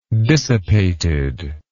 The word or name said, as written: dissipatedness